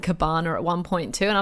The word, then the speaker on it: Gabbana: It all had to do with how we pronounce, now I've got to be careful, Dolce and Gabbana. – No, you did it again. Oh, it's Gabbana.